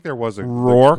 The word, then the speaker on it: Rourke: Rourke. Mickey Rourke? – Like York.